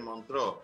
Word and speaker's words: Montreux: el libro en Montreux. – ¿Se pronuncia así? Montreux, Montreux, Montreux sí.